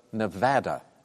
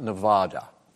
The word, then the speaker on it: Nevada: Its tourism commission puts a diacritical mark in the state's name showing the short A pronunciation. Bill. – What is Hawaii? – No. Kaberi or Corey? What is the state of Nevada, not Nevada?